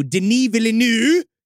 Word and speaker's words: Denis Villeneuve: So I knew that Dune 2, depending on what the H he was going to do, Denis Villeneuve, because now I know how to say his name.